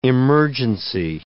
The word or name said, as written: emergency